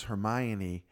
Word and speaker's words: Hermione: And then when we found out that it was Hermione, my firstborn, Devin, was like, I knew that the whole time.